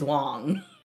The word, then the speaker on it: Wang: If you speak Chinese, it's literally the last name is Wong.